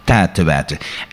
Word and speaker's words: tijd te wijten: Vandaag het woord, hopelijk zeg ik het goed, tijd te wijten. – Je zegt het absoluut goed. Tijd te wijten.